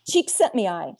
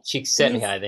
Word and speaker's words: Csikszentmihalyi: Csikszentmihalyi. – Csikszentmihalyi, thank you.